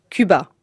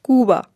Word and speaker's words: Cuba: Un français dira Cuba. En espagnol, on dit Cuba. C'est de ce U-là dont je vous parle, le U de Cuba. Votre U prononcé U dans Cuba, c'est notre U écrit O-U dans Je souhaite.